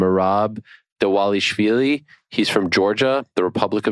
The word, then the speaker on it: Dvalishvili: D-V-A-L-I-S-H-V-I-L-I. – Got it. – Yeah. Well, because I'm saying Dawalishvili, there's no W in there, but that's how you pronounce his name.